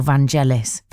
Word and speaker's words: Vangelis: Is it Vangelis or Vangelis? – Vangelis, soft, I think. Soft G.